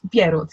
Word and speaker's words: Bierut: But is it Bierut? Is that how you pronounce? – Bierut, yeah.